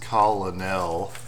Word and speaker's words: colonel: You're pronouncing it in this strange way. It's obviously pronounced colonel. – Yeah. Yeah. It's a Kentucky colonel.